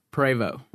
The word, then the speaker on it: Prevost: Is the ST silent? In Prevost. – And I've never heard anybody say Prevost. – It's pronounced Prevost.